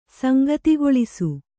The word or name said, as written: ಸಂಗತಿಗೊಳಿಸು